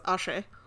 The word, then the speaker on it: axé: Apparently, good vibes in Portuguese is axé.